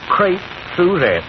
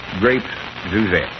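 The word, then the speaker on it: Crêpe Suzette: Crepe Suzette. – Grapes Suzette.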